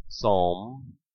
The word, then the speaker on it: psalm: The old English word psalm was corrected to psalm so that it would be similar to the Latin psalmus or the Greek psalmos. The P was never, ever pronounced in English.